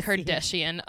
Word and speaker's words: Kardashian: Through my CNN, Rob Kardashian on, and I said it right, Kardashian, on Instagram.